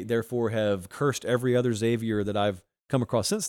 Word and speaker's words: Xavier: I go really, really hard on the X, and it's just Xavier. It's not Xavier. But I had a kid, Xavier Hall,